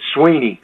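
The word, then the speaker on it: Swinney: At Dabo, and I get all kind of pronunciations of that, and it's Sweeney, as in just like E-E.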